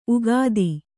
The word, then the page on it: ಉಕ್ಥ: uktha